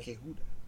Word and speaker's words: Quijudo: How do you say that? Quijedo? Quijedo. Quijedo. – Yeah, don't. – Quijedo. – Soft J. – Quijudo. Quijudo. – Quijudo. – Quijudo. There it is.